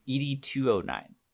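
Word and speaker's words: ED-209: Do you want me to ED9 you? – You're saying it wrong every ED209.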